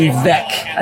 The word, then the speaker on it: Veeck: The former Cubs president, William Veeck, I believe it's pronounced, William Veeck, he added ivy to the outfield walls of Wrigley in 1937.